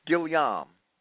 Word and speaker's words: Guillaume: As to Marx, Guillaume observes, did I butcher that guy's name, Guillaume? – It's Guillaume. – Guillaume observes. – It's actually the French equivalent to Williams.